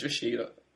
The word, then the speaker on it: Rashida: Don't think that's her name. – Yes, it is. Rashinda Jones. Am I pronouncing it wrong? – Thinking about it, an N. I think it's Rashida. – Oh, Rashida. Okay.